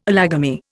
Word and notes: allogamy: Indian

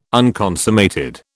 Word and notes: unconsummated: British English

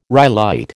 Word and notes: rhyolite: American English

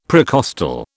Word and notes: precostal: British English

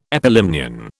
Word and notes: epilimnion: British English

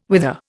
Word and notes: whither: Indian

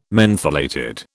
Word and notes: mentholated: British English

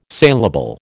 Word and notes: saleable: American English

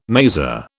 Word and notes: maser: British English

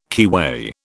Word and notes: keyway: British English

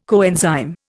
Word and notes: coenzyme: Indian

